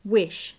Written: wish

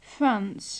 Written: France

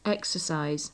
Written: exercise

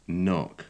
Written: knock

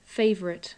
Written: favourite

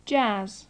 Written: jazz

